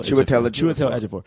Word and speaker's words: Ejiofor: I think it's Chiwetel Ejiofor.